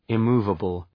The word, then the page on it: immovable: ı’mu:vəbəl